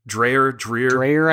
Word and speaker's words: Dreher: Like, I guess, and I need help understanding this, because so somebody like Rod Dreher, am I saying that right? Dreher, I think, yeah.